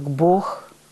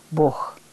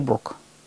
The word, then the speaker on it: Бог: И третье правило – это когда особые слова, такие как «Бог», «Богородица», мы должны произносить с «г» фрикативным. «Бог», а не «Бог». Вот это очень режет слух, когда говорят «Бог его знает», какой правый, левый. Древняя, старинная произносительная норма – только «Бог», «Богу», «Богом данные» и так далее.